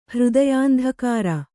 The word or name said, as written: ಹೃದಯಾಂಧಕಾರ